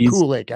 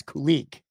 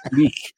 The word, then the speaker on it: Kulich: It's not Kulik. It's Kulik. – Kulik. – I got to make sure I don't butcher it. You kind of have to like get a little phlegmy like in the back of the back of the mouth. Like, Kulik.